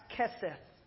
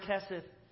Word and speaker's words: hesed: I believe it's pronounced Kesed. H-E-S-E-D.